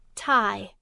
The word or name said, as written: tie